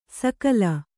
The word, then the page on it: ಸಕಲ: sakala